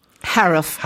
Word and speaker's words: Haruf: Haruf? – Haruf.